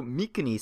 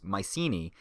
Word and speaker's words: Mycenae: In terms of language and pronunciation, I'm going to go with the standard English way of pronouncing Greek names. So Mykenae stays Mycenae, and Macedonia stays Macedonia. I know the hard K is more accurate, but I think people have more familiarity with the soft C.